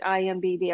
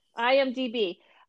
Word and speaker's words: IMDb: IMDb or is it IMBD? I always get it wrong. – You got it right. – IMDb. I got it right. IMDb.